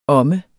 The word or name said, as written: omme